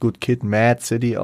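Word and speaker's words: good kid, m.A.A.d city: natürlich spricht man das gut Kid Mad City aus. Das Mad ist aber nicht als MAD ganz normal klassifiziert, also als verrückt, sondern äh, Mad wird geschrieben.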